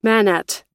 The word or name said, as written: manat